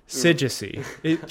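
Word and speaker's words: Syzygy: Yeah, I guess with the scissor G, which is, did I say it right? – Sigisi. Trust me, I had to ask Google how to pronounce it.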